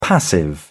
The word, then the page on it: passive: British English